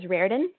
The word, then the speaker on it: Rarden: My last name is Rarden, and my mom has always said, think of it like a rare done steak, if that helps.